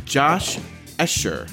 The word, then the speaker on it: Josh Escher: This week, we have Josh Escher.